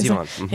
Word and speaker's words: Zevon: Yeah, it was Zevon.